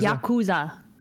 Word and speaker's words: Yakuza: With an ER on there, like Yakuza. – Yeah, but you're not actually mispronouncing it, though. – I feel like I'd have to be really into like pronouncing it to say it right. Yakuza. No. – You can say Yakuza or Yakuza.